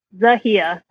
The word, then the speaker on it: Zahiya: Lisa, Zahiya. Zahiya.